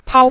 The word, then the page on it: pao: pao5